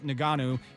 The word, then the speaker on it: Ngannou: Uh, Ngannou, yeah, I believe. Ngannou, yeah.